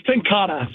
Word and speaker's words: Tenkate: I know the life you've led has been, you know, a roller coaster, but with a and now I've got Hellas by Tenkate. Tenkate, is that? I want to say it right. – It's Tenkata. So but it's spelled like Tenkate. – Yeah. – But it's, yeah, Tenkata.